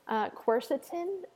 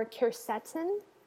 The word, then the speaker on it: quercetin: And then the other supplement that often comes up for arthritis, especially when it's got a rheumatic component, I'm going to give you two pronunciations because everybody pronounces it differently, quercetin or quercetin. So it's quercetin, I think.